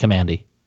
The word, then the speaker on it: Kamandi: That's pronounced Kamandi.